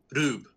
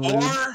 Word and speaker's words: R/B: It's pronounced Ultraman Rube. But as the way Japanese works is the L's and the R's are the same letters.